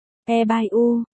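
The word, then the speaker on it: E bai u (二百五): Thực tế là trong ngôn ngữ của Trung Quốc 250 được phát âm là E bai u, nghĩa là 250 nếu dịch nghĩa đen.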